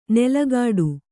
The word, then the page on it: ನೆಲಗಾಡು: nelagāḍu